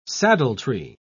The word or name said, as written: saddletree